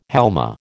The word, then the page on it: halma: American English